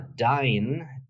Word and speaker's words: Dyn: Dort hat unter anderem auch Christian Seifert gesprochen, den wir noch als DFL-Chef kennen, jetzt als Inhaber der Firma Dine, D-Y-N geschrieben, D-Y-N und eben Dine ausgesprochen.